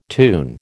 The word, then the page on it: tune: American English